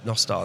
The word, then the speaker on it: Nostr: Yeah, so an important takeaway from this is that it's Nostr, not Nostr. – It's Nostr, yeah, Nostr, Nostr. Because Nostr in Welsh means good night. So yeah, Nostr, that's how it's supposed to be pronounced.